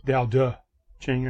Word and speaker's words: Tao Te Ching: The Tao Te Ching is actually spelled with a T, even though it's pronounced Tao De. Tao De Ching, as a matter of fact.